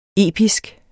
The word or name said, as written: episk